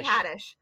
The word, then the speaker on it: Haddish: Haddish. – Yes. Why did I say Haddish? Because some other people do. – And it rhymes with radish.